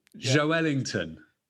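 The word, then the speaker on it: Joelinton: Like a jazz player, not Joellington. That's how they say it in South America, where I'm obviously am at the moment. And the commentary is Joellington.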